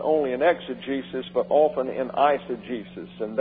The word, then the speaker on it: exegesis: Exegesis comes from the Greek word exegesis. Already screwed up. That's E-X-E, long E, G-E, long E, S-I-S. And the G in Greek is always hard.